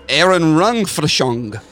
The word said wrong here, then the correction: Erinnerungsfälschung: It's pronounced, it's Erinnerungsfälschung. Because the two dots above the A make it a long A, so you go A.